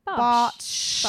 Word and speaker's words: Bartsch: Bartsch. Bartsch. – It's it's S H no S E H on the end. Bartsch.